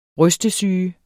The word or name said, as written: rystesyge